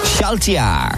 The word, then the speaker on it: Schaltjahr: And the German for this movie is Schaltjahr. – How do you spell it? – S-C-H-A-L-T-J-A-H-R.